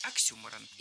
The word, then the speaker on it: Оксюморон: Ударение в этом слове нужно ставить на второй слог. Оксюморон.